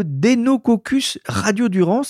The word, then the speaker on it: Deinococcus radiodurans: le tardigrade que certains connaissent et le Deinococcus radiodurans. Alors j'espère que je le prononce bien, mais ce sont des drôles de bêtes. – Très bien prononcé.